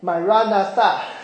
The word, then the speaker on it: Maranatha: 最后说，主必要来，就是你听过的 Maranatha。有听过这句话吗？ Maranatha 是一个亚兰文，重音应该放在第二跟第四个音节，应该念成 Maranatha，就是主一定会再来。